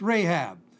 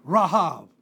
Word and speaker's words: Rahab: And we have the word Rahav, which means, it is R-A-C-H-A-V, R-A-C-H-A-V, pronounced Rahab. And so it's a long A in English. In Hebrew, it'd be Rahav.